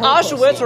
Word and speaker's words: Auschwitz: Well, it's in America we call it Auschwitz because the W is pronounced W in the English language.